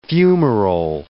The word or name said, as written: fumarole